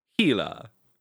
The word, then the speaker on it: Gila: The soft G. Gila. Gila.